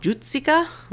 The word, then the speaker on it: Jucika: It's called Jutsika. I looked up a pronunciation, so hopefully that's close. It's spelled J-U-C-I-K-A, and it is a Hungarian comic strip that ran from 1957 to 1970 by Paul Paul Pustai?